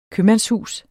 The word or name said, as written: købmandshus